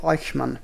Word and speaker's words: Eichmann: Adolf Eichmann. – Eichmann. Ah, niin, se, siis, ah, niin. – Adolf Laudut, Eichmann. Lausutaan alla. – Niin, mutta alkaa eellä. – Niin, totta. just.